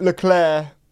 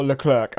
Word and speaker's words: Leclerc: Like, I think Leclerc or Leclerc are fine. I think that's just like a tone. If we're wrong, let us know. – I like the hard. If I was just going to spell it, I'd put a K at the end, like a kicking K. – Nice. – Kicking K, and it'd be a hard K, so it'd be like Charles Leclerc.